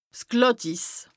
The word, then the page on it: 's Glàttiss: Bas-Rhin; Strasbourg